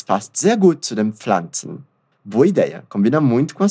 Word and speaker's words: Pflanzen: Es passt sehr gut zu den Pflanzen. Wunderbar. Percebe que a pronúncia de Pflanzen tem um detalhe. Normalmente nós falamos o som da letra P no começo, mas ele é bem suave. Pflanzen.